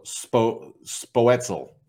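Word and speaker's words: Spoetzl: And they basically broke it down to say an S sound and then say poet and then zeal. – Okay. – So Spoetzel, something like that.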